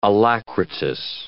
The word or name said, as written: alacritous